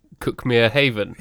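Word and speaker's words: Cuckmere Haven: And also nearby is the lovely village of Cookmere Haven, which is written Cookmere Haven, of course.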